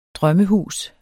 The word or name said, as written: drømmehus